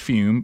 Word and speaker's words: Fum: That's T-R-Y-F-U-M. It's pronounced Fume, but it's spelled F-U-M.